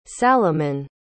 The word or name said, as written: saloman